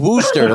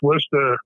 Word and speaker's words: Worcester: I always thought it was Worcester, like rooster with a speech impediment. So I was like, oh, great. I'm not going to remember that. – Yeah, it's Worcester. We pronounce it Worcester.